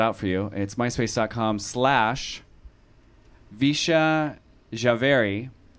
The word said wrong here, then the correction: Vishal Jhaveri: V as in Victor, I-S-H-A. Oh, I didn't even pronounce, I didn't even say it. I couldn't even read it right. It's Vishal Javeri. V-I-S-H-A-L-J-H-A-V-E-R-I.